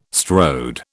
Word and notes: strode: British English

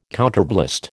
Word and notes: counterblast: American English

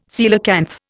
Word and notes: coelacanth: Indian

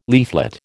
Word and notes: leaflet: American English

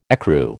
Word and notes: ecru: American English

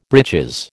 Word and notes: breeches: American English

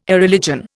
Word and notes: Irreligion: Indian

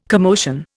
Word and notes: commotion: Indian

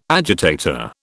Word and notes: agitator: British English

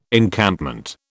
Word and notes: Encampment: British English

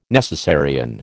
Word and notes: necessarian: American English

